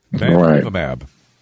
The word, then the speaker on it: bamlanivimab: Bamlanivimab. Bamlanivimab. – Yes, I told him. Bamlanivimab.